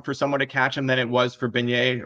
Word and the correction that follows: Beniers: or ben, Beniers.